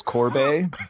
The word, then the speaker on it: Corbet: I believe his last name is pronounced like Sorbet. So it's Corbet.